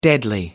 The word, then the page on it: deadly: American English